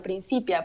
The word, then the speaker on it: Principia: Sí, bueno, pues justamente la idea es hacer una referencia a la principia matemática de Isaac Newton, pero, eh, pero pronunciar la palabra como en castellano, principia, porque me parecía muy interesante pensar en un principio femenino, ¿no?